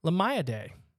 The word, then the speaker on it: Limiidae: It's a unique marine bivalve mollusk belonging to the family Limiidae, almost spelled like Lima Day.